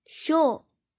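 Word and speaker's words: 勝: 胜负的胜，音读的时候读作 show show，比如 kesho sen kesho sen kesho sen 是决赛的意思。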